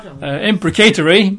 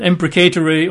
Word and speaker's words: imprecatory: And I don't hear the word pronounced very often, I've got to say, but in England, generally speaking, I hear it pronounced as imprecatory. I have heard it pronounced imprecatory, particularly in the US of A.